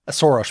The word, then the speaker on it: Soros: Soros, by the way, is the Hungarian pronunciation of Soros. That's why I'm saying it that way. I haven't just like developed a lisp randomly in this conversation.